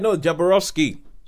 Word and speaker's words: Jodorowsky: It's a Jodorowsky film or something like that. He did another film called El Topo. – Jodorowsky.